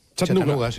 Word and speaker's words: Chattanooga: Chattanooga.